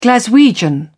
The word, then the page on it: glaswegian: British English